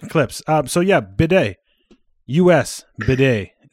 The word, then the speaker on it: bidet: I have a bidet, and it makes my butthole not chafed when I wipe.